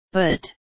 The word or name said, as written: but